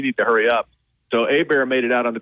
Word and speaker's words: Åberg: It's spelled A-B-E-R-G, but it's pronounced Åberg, and he's from Sweden, 24 years old.